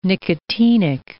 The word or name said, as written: nicotinic